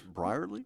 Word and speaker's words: Brierley: And Saru Brierley, I'm sorry if I'm saying that wrong. – I think it's Brierley. – Is it Brierley? See, I knew I'd say it wrong.